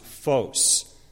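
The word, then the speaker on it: phos (φῶς): So the Greek word for light is pronounced phos.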